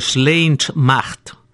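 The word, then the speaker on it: Slàinte Mhath: Algo así como Sleintmacht. Sleint, ou como casi como aquel Slonta que é de saúde, e Macht, pues, é un M-H-A-T-H.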